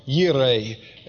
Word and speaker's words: Jireh: And Jehovah Jireh, or Yireh, as some scholars pronounce it, meaning the Lord who sees.